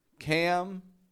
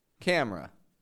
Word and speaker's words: camera: Cam-ra. Camera.